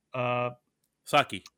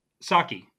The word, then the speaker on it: Psaki: Psaki. – Psaki.